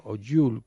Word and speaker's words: Yule: Jul, como se pronuncia. Y-U-L-E.